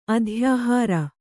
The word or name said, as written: ಅಧ್ಯಾಹಾರ